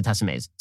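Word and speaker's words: Tessemae's: I'm eating salad now, thanks to Tessa Mays.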